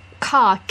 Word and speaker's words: Ka: Ka, K-A.